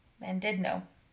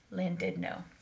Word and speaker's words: Llandudno: Landudno. Does not look like that is, it's literally L-L-A-N-D-U-D-N-O. Landudno. Landudno. – Landudno.